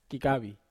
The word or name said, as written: kakavi